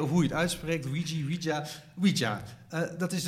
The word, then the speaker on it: Ouija: Ouija, zeg ik dat goed? Ouija, toch? Ik denk het wel, ja. Ouija.